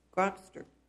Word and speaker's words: Grokster: In Grokster, is that how you say it? – Yes.